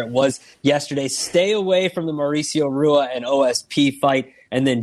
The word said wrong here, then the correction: Rua: It's Hua. – Who? – Hua. – Hua? – It's Shogun Hua. Hua. – Hua, Rua, Hua, Rua. – It's not Rua. I mean, it's Hua.